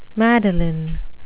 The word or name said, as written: madeleine